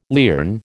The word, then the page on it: lierne: American English